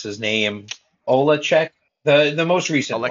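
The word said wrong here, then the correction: Oleksii Novikov: Alexei Novikov.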